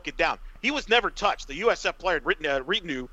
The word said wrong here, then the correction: Rideau: I can't pronounce his name well. – Rideau. – Thank you, Rideau. He drove in.